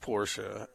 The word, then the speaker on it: Porsche: It's a Porsche. Secondly, Porsche. I only have one Porsche. It's pronounced Porsche.